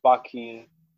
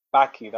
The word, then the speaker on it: Baki: Um, I finished off Baki. Baki. that's that's how it's spelled. B a k i um, mm.